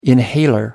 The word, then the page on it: inhaler: American English